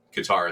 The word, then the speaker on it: Qatar: Let me ask you about Qatar, or Qatar. Now I'm hearing you have to pronounce it Qatar.